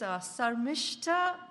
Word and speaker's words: Sarmishta: My name is Sarmishta, so I go by Shami, which is easier for most Americans. I don't want to trouble them with three-syllable names.